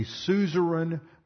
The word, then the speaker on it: suzerain: The other was called a suzerain vassal treaty. Now, we covered this, or I explained them in detail, both in the Genesis series and in the Old Testament introduction series. Suzerain is spelled S-U-Z-E-R-A-I-N, and suzerain refers to a lord or a master or a king.